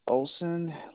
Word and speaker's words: Olsen: My name is Robert Olsen. Last name is spelled O-L-S-E-N.